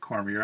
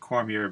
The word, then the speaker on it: Cormier: I believe his name is pronounced Robert Cormier. I was trying to find out whether it was Cormier or Cormier or Cormier, and I've heard it pronounced every which way, so I'm going to stick with Cormier because it sounds like what people from Boston would say.